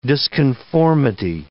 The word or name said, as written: disconformity